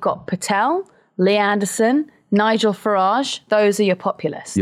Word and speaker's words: Farage: Now, what's Nigel Farage doing? I love the soft G that I've added to that. – Yeah. – Farage.